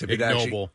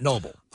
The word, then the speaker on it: Ig Nobel: And there's a word. I never know how it's pronounced. – Ig Nobel. – Yeah, right. So, and it's not noble.